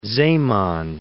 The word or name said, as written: Zeeman